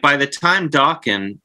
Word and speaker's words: Dokken: I know you're supposed to say, do you say Dokken? Like, Rockin', Dokken. – Yes, rhymes with Rockin'.